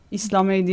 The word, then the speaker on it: İslamey: İslamey diye de okunuyor.